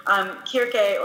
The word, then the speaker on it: Circe: So in the ancient context, it would have been with a hard C. It would have been Kirke or Kirpi in Greek is how it's said.